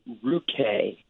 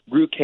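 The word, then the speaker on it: Ruchè: Go with Ruche. Ruche, it's spelled R-U-C-H-E with a little thing over the E.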